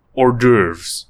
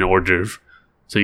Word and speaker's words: hors d'oeuvres: Hors d'oeuvres. There's an H there, but, you know, conveniently swallowed, and you swallow the H when you're eating hors d'oeuvres, because there's not enough of a portion in the hors d'oeuvre, so you gotta eat the H.